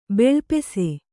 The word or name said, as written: ಬೆಳ್ಪೆಸೆ